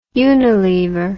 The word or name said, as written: unilever